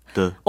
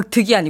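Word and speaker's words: 드 (得): 중국은 뭐 밥 먹다 아니면 마시다 이런 구체적인 의미가 없는 조사는 다 경성어로 발음해요. – 아, 경성어로. – 네, 그래서 뜨. – 뜨. – 뜨. – 드. – 얻을 득자니까 드. – 더 득이 아니고 드.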